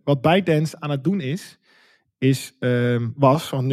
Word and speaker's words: ByteDance: Is dat ByteDance, en dat is de maker van TikTok, als ik het goed zeg.